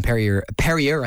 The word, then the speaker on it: Perrier: Marco Rowe, and he's done Intermission and Perrier, I think I specifically was told to pronounce it that way, Perrier's Bounty.